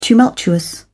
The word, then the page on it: tumultuous: British English